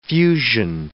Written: fusion

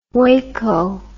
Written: huayco

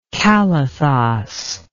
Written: kalathos

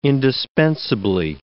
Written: indispensably

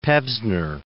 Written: Pevsner

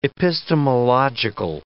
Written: epistemological

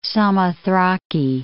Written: Samothraki

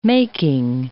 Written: making

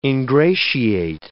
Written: ingratiate

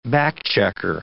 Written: back-checker

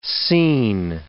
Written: scene

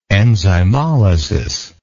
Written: enzymolysis

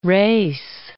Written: Race, Cape

